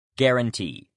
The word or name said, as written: guarantee